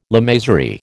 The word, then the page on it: lamasery: American English